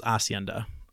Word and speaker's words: hacienda: I knew in this story that the H is silent. It's Hacienda. Hacienda.